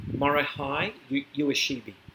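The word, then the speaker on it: Morihei Ueshiba: Morihei Ueshibi.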